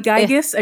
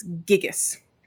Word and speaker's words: Gigas: I did finally Google how to pronounce it's Gigas clam. – Gigas clam. – Okay. I wanted to be Gigas. I really did. It's Gigas.